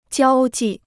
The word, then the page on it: 交际: jiāo jì